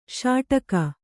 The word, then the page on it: ಷಡಕಿ: ṣaḍaki